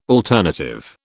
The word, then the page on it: alternative: British English